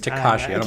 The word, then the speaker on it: Tekashi: Tekashi, sorry, I've been mispronouncing it. It's Tekashi.